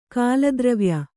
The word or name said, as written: ಕಾಲದ್ರವ್ಯ